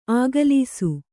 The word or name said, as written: ಆಗಲೀಸು